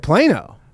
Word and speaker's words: Plano: Oh, well, I was just shocked you said Plano.